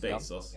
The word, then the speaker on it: Bezos: Men jag läste ju också Amazon och Jeff jag vet inte om man uttalar Jeff Bezos efter. – Ja. Men det är mm. Bezos eller? Bezos. Bezos. Ja. ja.